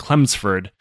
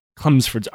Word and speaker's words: Chelmsford: Now, I've heard this pronounced that Clemsford bastard. And Chelmsford. So I'm going to go with Clemsford because I'm American and I'm dumb.